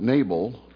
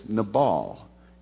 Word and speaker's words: Nabal: we always pronounce it Nabal, but it's Nabal.